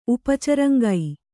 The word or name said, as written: ಉಪಚರಂಗೈ